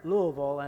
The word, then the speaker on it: Louisville: The weatherman in Louisville, and I understand you have to say that with three syllables and just kind of go right straight through it there.